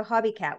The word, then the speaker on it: Hobie: Well, it's pronounced Hobie cat. – Oh, Hobie. Okay, yep. There we go. – Not a Hobie cat. Not like, you know, knitting socks with your cat.